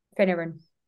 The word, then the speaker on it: Finneran: How do you say her second name? Siobhan. – Finneran. – Finner because uh, I'm like, is it Finnernan?